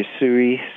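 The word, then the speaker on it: Usui: Did I pronounce this right? A Yusei Reiki Master? – Yasui Reiki Master.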